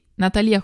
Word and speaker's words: Natalia: Natalia con l'accento sulla I, Guerrieri, è nata nel 1991.